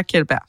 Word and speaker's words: Kjeldberg: Og så gik jeg ind og googlede, og så jeg sådan, du har 100% ret i den måde, du udtaler det på som noob, men som kender, siger man altså bare Kjeldberg.